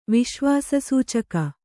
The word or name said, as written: ವಿಶ್ವಾಸಸೂಚಕ